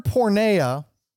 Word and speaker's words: porneia: Now, the word porneia is the Greek word in the text behind this term sexual immorality.